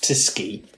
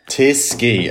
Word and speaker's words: Tychy: You get into T-Y-C-H-Y. And I've realised, actually, a colleague has explained to me recently that actually the C, I think in Polish, is pronounced with, like, you know, like the S sound. So I think it's probably that we look at it as being the Tychy, as the spelling. Yeah. But that I think that's pronounced Tysky. – Tysky, okay. – Yeah, so the C and H is the sk sound.